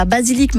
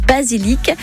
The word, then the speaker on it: basilic: Alors, je vous ai un peu aidé parce que à Marseille, où j'ai vécu pendant de longues années, on dit pas basilic, mais on dit basili. Ça vous aurait pas aidé. Mais là, je voulais être sympa, donc je vous ai tout prononcé. J'ai dit basilic. Et c'est bien par un C que ça se termine.